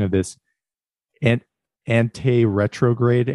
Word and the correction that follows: anterograde: amnesia, anti-retrograde versus retrograde amnesia. – Anterograde amnesia. – Anterograde, yes.